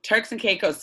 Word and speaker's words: Turks and Caicos: Turks and Caicos.